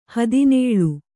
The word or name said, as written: ಹದಿನೇಳು